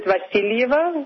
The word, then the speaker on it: Vasilieva: Well, Russians pronounce it Vasilieva with a stress on the second syllable, but your pronunciation is quite good.